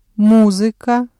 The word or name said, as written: музыка